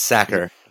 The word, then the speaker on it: Sacker: We are Andrew Satcher here. Did I say your name right? – It's Sacker. The H is like a K.